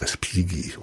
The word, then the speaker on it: Respighi: Pass auf, wenn da GH steht im Italienischen, dann spricht sich das nur wie G, nicht wie G. – Mhm. Also der heißt Respigi. und so.